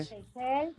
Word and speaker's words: Seychelles: Seychelles, lo estamos diciendo bien, lo decimos bien. ¿Cómo se dice? – Seychelles, sí, perfectamente. Sí.